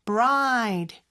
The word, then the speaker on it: bride: 그래서 b하고 r로 시작하지만 두 개의 철자는 마치 하나처럼 발음이 돼야 돼요. 그럴 때는 뒤에 거 먼저 연습하면 좋다고 했죠. 그래서 ride, ride, bride, b는 살짝 앞에 붙여주면 됩니다.